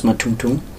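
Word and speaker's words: Mathungthung: My real name was Matumtum.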